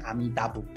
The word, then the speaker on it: 阿弥陀: 可是日文它不，它念阿弥陀佛的时候，它又不是念阿弥陀佛，它念阿弥达不达啊，阿弥达。就是阿弥陀，它念成阿弥达。事实上，反而日文的阿弥达比较贴贴近于阿弥陀佛的梵音的发音。